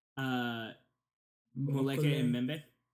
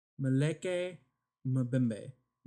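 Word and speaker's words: Mokele-mbembe: And how do you say it again? – Muleke Mbembe. – Muleke Mbembe. – Mbembe.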